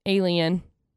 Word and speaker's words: AEN: It's called the A-E-N. – Okay. – Okay. Pronounced alien.